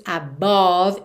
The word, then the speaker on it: above: Atenção ao som dessa palavra, que tem um O aqui no meio, certo? A-B-O-V-E, mas você não diz above e muito menos above, ok? Esse E final não é pronunciado. E o O do meio não é realmente um O, above, e sim above, above.